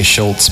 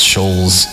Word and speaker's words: Schulz: I think that's how you say it. Some people say Schultz, but I think it's Schulz.